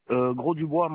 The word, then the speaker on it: Gros Dubois: Gros Dubois, Manuel. – Alors, ça s'écrit comment? – G-R-O-S. D-U-B-O-I-S.